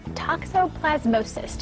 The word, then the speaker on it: toxoplasmosis: Pet talk this morning, toxoplasmosis, did I say it correctly? – Very good, very good.